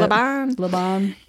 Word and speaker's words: Laban: Am I saying that right? – That's how I would say it. – Laban.